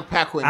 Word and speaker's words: Paquin: This movie came out in 2007, I believe, and it stars Anna Paquin, which this is all, is it Paquin, right? Is that how you say it? Paquin? Paquin? – It's Anna Paquin.